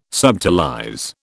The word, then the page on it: subtilize: British English